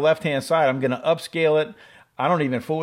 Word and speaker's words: Upscayl: So this application is called Upscale, and it's spelled UPSCAYL, right?